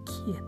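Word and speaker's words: 葵枝: 葵に枝と書いてキエって読むん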